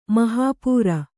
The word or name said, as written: ಮಹಾಪೂರ